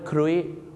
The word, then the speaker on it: cruise: cruise เหมือนทอมครูซนะฮะชาวไทยนะครับบางทีไปออกเสียงว่าครุยนะฮะอย่างเช่นเราจะไปทานข้าวล่องเรือกันนะครับบนแม่น้ำก็จะเรียกว่าอะไรครับเรียกว่า dinner cruise แต่ผมเคยได้ยินนะครับไปถามฝรั่งว่า would you like to go on a dinner cruise